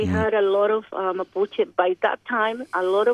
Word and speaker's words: Mapuche: Were the Maputo, can I pronounce it? – Mapuche. – Mapuche. Were the Mapuche...